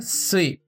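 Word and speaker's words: sui (すい): Esse kanji tem a leitura mizu, que significa água de modo geral, mas também tem a leitura sui, sui, que é bastante vista dentro de outras palavras, como a palavra suyobi, suyobi, que é quarta-feira.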